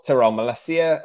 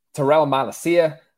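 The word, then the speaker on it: Tyrell Malacia: We'll talk Terrell Malacia as well. I'm sorry, I pronounced that name wrong. Terrell Malacia.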